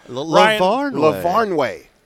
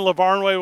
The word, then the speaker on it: LaVarnway: And then, obviously, Ryan Lanaway is going to join us. I totally butchered that. Like, I promise I would. – LaVarnway. – Ryan LaVarnway will join us,